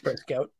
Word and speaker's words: Prescott: Prescott.